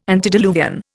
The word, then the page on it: antediluvian: Indian